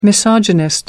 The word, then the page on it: misogynist: American English